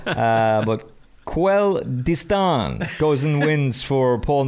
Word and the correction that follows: Quel Destin: Uh, Nick, the correct pronunciation is. – Quel Destin.